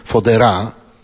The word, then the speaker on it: Fodera: We Włoszech jest bardzo popularny taki Manuel Fodera, tak się wymawia jego nazwisko, włoski chłopiec, który zmarł 20 lipca 2010 roku, miał zaledwie 9 lat.